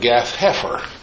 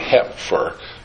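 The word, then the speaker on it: Gath Hepher: I would pronounce it Gath Hefer, because P-H I would pronounce as F. And I think calling it Hefer lends itself to confusion and misunderstanding. So I've heard somebody call it Hepfer. They put the P in and then they put it back in as part of the PH.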